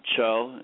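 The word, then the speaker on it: TCHO: and it's called Cho. It's T-C-H-O is how they spell it,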